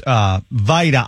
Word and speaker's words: VITA: And it's pronounced VITA. I said VITA. I see that spelling, and I just think Vita Vea from the Bucks. So my apologies. – Either way, since it's an acronym.